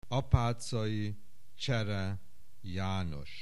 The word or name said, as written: Apaczai Csere Janos